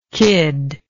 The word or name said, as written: Kyd